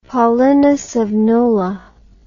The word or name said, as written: Paulinus of Nola